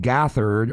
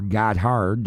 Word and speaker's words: Gotthard: It's a band that's going by the name of Corleone out of Switzerland, but it's actually the lead guitar player from a band called Gothard, or Gotthard, depending on how you want to pronounce their name. It's G-O-T-T-H-A-R-D.